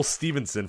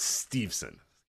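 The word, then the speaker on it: Steveson: But I say Gable Stevenson for an entire show and nobody cares. But it's Stevenson.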